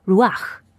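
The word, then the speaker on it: Ruach (רוח): 希伯来文的灵念作 Ruach，特别的是在圣经里第一次提到灵这个字，并不是用在人类，而是指神，神的灵。创世纪的第一章第二节，地是空虚混沌，渊面黑暗，神的灵运行在水面上。灵 Ruach 代表的是神的灵在，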